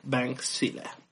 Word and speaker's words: Bankzilla: Se escribe con B grande y pues lo demás como si fuera Godzilla. Bankzilla.